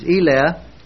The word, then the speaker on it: Eleh: But we checked it out and found out that the Hebrew for these is Eleh, E-L-E-H, long E.